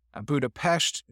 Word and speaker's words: Budapest: Budapest, if you want to pronounce it the Hungarian way. Budapest, if you want to sound like an American.